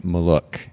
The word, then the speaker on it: Malocu: And you were pronouncing your name for me before we got started, Malocu.